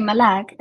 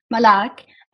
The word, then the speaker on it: Malak: I always remember him with one tiny thing he used to do, especially with me, because I hate it when people mispronounce my name. I hate it when people say Malak, and he would always, always call me Malak.